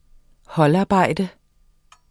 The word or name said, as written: holdarbejde